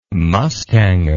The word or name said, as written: mustanger